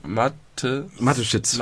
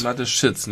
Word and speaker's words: Mateschitz: Wie spricht man die aus? – Mateschitz. – Mateschitz, nicht Sitsch. Schitz? – Schitz.